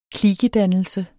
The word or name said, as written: klikedannelse